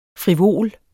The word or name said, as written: frivol